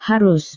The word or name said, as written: harus